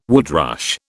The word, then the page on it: woodrush: British English